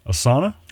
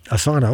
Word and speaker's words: asana: Asana? – Asana, okay.